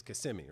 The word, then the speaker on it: Kissimmee: It's not it's me. It's Kissimmee, right? Kissimmee. That's, that's yeah. how they say it.